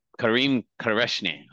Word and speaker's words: Karim Kerachni: Everybody, please welcome Karim Kerachni.